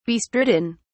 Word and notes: bestridden: British English

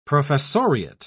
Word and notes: professoriate: British English

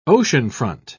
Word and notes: oceanfront: British English

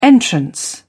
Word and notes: entrance: British English

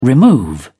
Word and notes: remove: British English

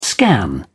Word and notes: scan: British English

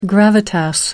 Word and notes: gravitas: American English

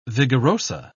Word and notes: vigoroso: British English

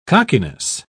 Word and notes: cockiness: British English

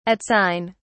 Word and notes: at sign: British English

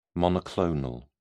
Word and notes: monoclonal: British English

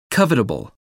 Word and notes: covetable: American English

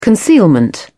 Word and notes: concealment: British English

